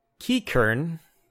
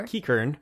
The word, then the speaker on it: Qiqirn: I go Kikern. – Sure. – Q-I-Q-I-R-N. – Sure. – Kikern.